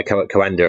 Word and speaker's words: Coanda: Yeah, the Coanda effect.